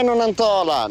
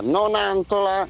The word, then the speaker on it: Nonantola: Non è Nonantola, Nonantola. – Va bene. Sì. – Si dice Nonantola.